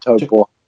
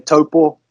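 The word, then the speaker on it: Taupō: Yeah, obviously in Taupo. – Taupo. – It's a small fly tying store. Yeah, Taupo. Like your toe and the paw of a cat.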